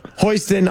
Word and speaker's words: Hostin: Let's get right into a sexual assault case covered by Sonny Hostin on The View. I don't even know how do you say her name? – Hostin. – Hostin.